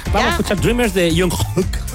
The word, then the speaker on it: Jungkook: Es porque lo pronuncias mal. – ¿Es Kok? – No, es Jungkook. – Ah, Jungkook. – Hook. – Hook. ¿Cómo? – No es K de K, es como de la garganta.